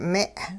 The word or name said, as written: me'